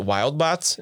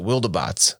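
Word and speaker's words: Wildebots: And then the Wildabots show up, which the whole time I read this, I pronounced it Wildbots in my head. – Yeah, so did I. – But then I watched part of the animated movie of this that's on Netflix. And they pronounced it Wildabots. And I was like, well, that makes sense. There's an E in there. It's like Wildebeest.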